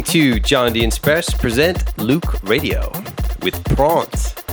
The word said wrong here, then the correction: Pawns: Yeah, I'm still laughing about my mispronunciation of Pawns by Casey Bourne. It is not prawns, it's pawns.